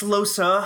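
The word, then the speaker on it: Lohse: Is that how you pronounce it? Lose. – I think it's Losa. I think it's a Losa. – Soft. – Yeah, Losa. – Yeah, Losa. – Losa. – It's French, you know. Yeah. If we had Ren on this podcast, I'm sure she could confirm for us that it's French.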